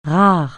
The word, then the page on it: rare: francais